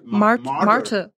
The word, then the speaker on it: martyr: Wie spricht man es denn auf Englisch? Märtyr. – Martyr. – Wie das Tier? Nein. Ja, genau.